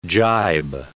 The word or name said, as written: jibe